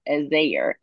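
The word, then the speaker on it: Azayir (עֵזֶר): So the word, as I said last week, it's pronounced as Azayir in Hebrew, and it just simply means help.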